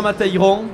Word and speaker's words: Matayron: votre site internet pour prendre enseignement, c'est quoi Pierre Matayron, mon nom.